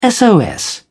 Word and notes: sos: British English

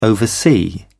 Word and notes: oversee: British English

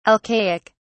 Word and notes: alcaic: British English

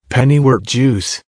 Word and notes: pennywort juice: American English

